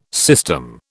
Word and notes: system: British English

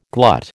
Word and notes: glut: American English

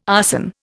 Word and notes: arson: Indian

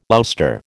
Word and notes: ouster: American English

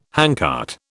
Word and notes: handcart: British English

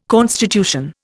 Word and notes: constitution: Indian

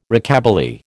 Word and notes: Rockabilly: American English

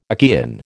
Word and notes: Achaean: American English

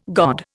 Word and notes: god: Indian

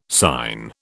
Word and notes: syne: British English

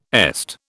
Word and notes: EST: British English

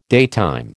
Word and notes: daytime: American English

